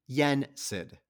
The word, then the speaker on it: Yensid: Yensid.